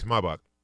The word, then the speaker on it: Maybach: It's Maybach.